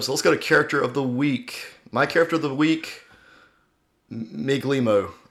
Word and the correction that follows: Migliamo: I believe you say it Migliamo.